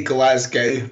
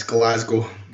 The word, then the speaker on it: Glasgow: Is that how Americans say it? Glasgow or Glasgow? – They say Glasgow, but it's Glasgow.